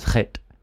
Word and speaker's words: chet: The letter is chet. – Chet. – Chet. – Yeah. I decided I'm going to learn the Hebrew alphabet. – Chet.